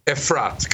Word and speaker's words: Efrat: Is it Efrat? Is that right? – Efrat, correct.